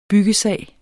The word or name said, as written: byggesag